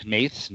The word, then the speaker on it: Hnath: Very, very, very well written by Lucas, however his last name is pronounced, H-N-A-T-H, Nath, Nath.